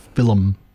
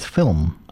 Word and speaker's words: film: I know that a lot of the older folks I've grown up around used to say it Philum. – Yeah, my grandmother's one of them, Philum. – Yeah. – Yeah. – Whereas and the modern pronunciation is film.